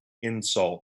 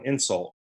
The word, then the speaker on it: insult: I can't get over Scott's enunciation on insult. Insult. He put all the emphasis on the wrong syllable. Insult. It's called an insult.